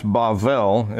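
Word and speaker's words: Bavel (בבל): Therefore, its name was called Babel. It's actually pronounced Bavel in Hebrew,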